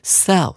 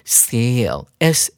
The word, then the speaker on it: sale: For sale，同学们要特别注意它的发音哦，千万不要念成它的动词形式 sell 的发音。 S E L L，我们要念成 sale，S A L E，这是一个名词，指的就是销售。